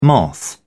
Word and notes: moth: British English

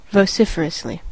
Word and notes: vociferously: American English

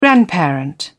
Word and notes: grandparent: British English

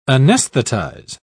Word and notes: anesthetize: British English

